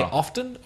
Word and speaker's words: often: You can say often or you can say often. – What do you say? – I'm fairly sure that I always say often. I pronounce the T.